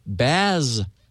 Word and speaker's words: Basilisk: It's a legendary reptile reputed to kill with a glance, pronounced Basilisk.